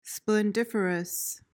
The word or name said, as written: splendiferous